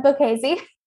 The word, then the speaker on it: Bocchese: With me today, I have Julia, how do you say your last name? – It's Bocchese. – Julia Bocchese.